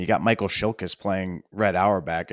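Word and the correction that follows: Chiklis: So, or Michael Shiklis, sorry, said his name wrong.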